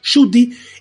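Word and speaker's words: Ncuti: No sabíamos, es Jyoti, se escribe Mkuti, pero se pronuncia Jyoti,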